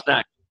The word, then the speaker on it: Bosnak: Yes, Bosnak.